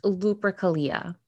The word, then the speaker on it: Lupercalia: I read that the earliest possible origin of Valentine's Day is a pagan holiday, I think pronounced Lupercalia.